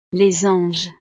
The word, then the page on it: les anges: frances